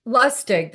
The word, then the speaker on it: Lustig: Lustig. That's it. I knew I was saying it wrong.